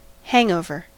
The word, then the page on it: hangover: American English